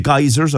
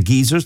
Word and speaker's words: geysers: And one more thing, this caller says they've been to Iceland, they've seen the geysers or the geezers, that's the way they're pronounced, they're brilliant, they're beautiful, it's a beautiful country, and the people in Iceland are as tough as nails.